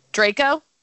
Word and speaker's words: Draco: Draco? – Yeah.